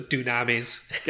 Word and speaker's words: Dunamis: I keep seeing Dunamis and thinking tsunamis. Dunamis? – Why? What? – Because it's spelled like tsunami, but with a D instead of a TS. – Okay. – So Dunamis.